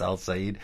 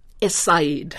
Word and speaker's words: Es-Sayed: Do Noam Chomsky's theories about learning languages apply to, and forgive me for not remembering the real pronunciation of this, Al-Sayed? El-Sayed? – Es-Sayed. – Es-Sayed. Okay, I'll try to remember that, like Edward Said.